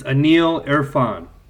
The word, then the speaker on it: Aneel Irfan: I gotta say your name right, dude. Say it for me. – It's Anil Irfan. – Okay. It's literally phonetic, Scott.